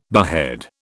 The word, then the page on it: behead: British English